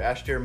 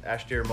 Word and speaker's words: Aistear: And great that we have a new sponsor here, Ashtier Mobile Irish Dance Academy with Chelsea.